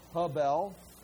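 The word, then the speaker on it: Hebel (הֶבֶל): it's H-E-B-E-L, Hebel. It's pronounced H-E-H, H-E-H, Bel, Hebel.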